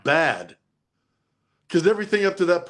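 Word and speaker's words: bad (בד): The cool part of that is that word alone in Hebrew is B-A-D, bad. – Really? That's the word for alone. Okay. – That's even how you pronounce it, is bad. Wow. With an extra emphasis on the D, bad.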